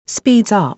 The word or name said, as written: speeds up